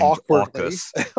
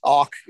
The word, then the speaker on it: AUKUS: And the AUSOC, or however we pronounce this now, strategic relationship reflects the fact. – Awkwardly named AUKUS. – AUKUS. Yes, indeed. And all of this sort of, you know, reflects a reality that the U.S.